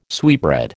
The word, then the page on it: sweetbread: American English